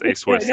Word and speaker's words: ASWIS: Did I say that right? – Yep, that's correct. That's ASWIS.